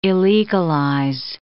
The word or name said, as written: illegalize